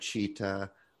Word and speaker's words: citta: And this is commonly, citta is spelled C-I-T-T-A. It's pronounced citta.